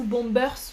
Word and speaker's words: bombers: On dit que c'est un blouson et un type de blouson, c'est le bomber Par exemple, donc bomber ou bombers en français, on dit souvent bomber. d'ailleurs, on prononce euh, le S.